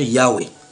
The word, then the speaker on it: Yahweh: Ils disent, voici ce qu'ils citent dans leur traduction, « Bien que nous inclinions à considérer la prononciation Yahweh comme la forme la plus correcte, nous avons retenu la forme Jéhovah.